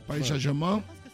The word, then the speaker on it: Paris Saint-Germain: Y bueno, entonces, digamos que lo que determina que él se quede en el Paris Saint-Germain, ¿lo he dicho bien? – Ajá. – Bien. – ¿Ves que está bien? – Paris Saint-Germain. Sí, sí.